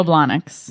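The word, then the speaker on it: Blahnik: By the way, is Blonics, that's not how you say it, right? – It's Manolo Blonics.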